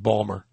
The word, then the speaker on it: Baltimore: And they would tell me, you know, it's you pronounce it Balmer.